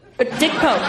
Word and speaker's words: Pope: For achievement in cinematography, the nominees are Dick Poop, Dick Pope, or Mr. Turner.